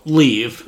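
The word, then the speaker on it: Liev: What does that have phonetically, like, on here? – Yeah. L-E-E-E-V. – So, Leave. – Leave, yeah.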